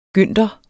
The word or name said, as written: gynter